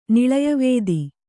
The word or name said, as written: ನಿಳಯವೇದಿ